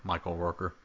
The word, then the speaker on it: Michael Rooker: That was Michael Rooker.